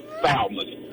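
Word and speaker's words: Thalmus: No, it's Salmon. – Oh, Salmon. Chuck, how do you spell it? – It's T-H-A-L-M-U-S.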